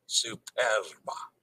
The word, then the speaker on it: Superba: So okay, Dennis is saying superba. Superba. Roll the R. Superba. Superba. Okay, I got you.